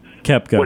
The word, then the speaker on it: Koepka: And what I was really shocked about was on 18 when Rahm and Koepka got, I think it's his name, Koepka, I don't know how you pronounce it. – Koepka.